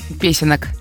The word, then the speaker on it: песенок: Спасибо за позитив, который с утра дарите, и веселых песенек, да? – Песенок. – А, песенок, окей. Я думаю, песенек. – Песенок. Ты поаккуратнее повторял бы это слово. Там чуть-чуть ударение не туда, и все.